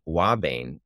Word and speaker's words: ouabain: And the active ingredients are cardiac glycosides, including one called Wabain, which is spelled O-U-A-B-A-I-N.